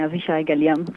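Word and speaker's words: Avishai Gal-Yam: Forse è meglio farlo dire direttamente a lei, come si pronuncia? – No, l'ha detto bene, Avishai Galiam.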